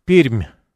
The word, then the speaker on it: Пермь: Анатолий Анатольевич меня тут же поправляет. Правильно произносить Пермь.